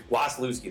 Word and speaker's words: Wasielewski: She's a sweet girl, and I butchered her last name, so I'm going to let her say it in the beginning of the interview, and she'll do a better job than I did. – Wasielewski, there you go.